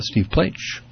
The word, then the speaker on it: Plaich: That's Steve Plaich. Rhymes with H, for those who want to check him out.